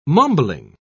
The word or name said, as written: mumbling